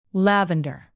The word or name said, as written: lavender